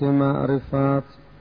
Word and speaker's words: معرفت: در درجه اول میگویند لغت معرفت از 5 حرف تشکیل شده، از حرف میم، حرف عین، حرف ر، حرف فا و حرف ه، که البته ما این ه رو در تلفظ و در گفتار به صورت ت میخوانیم،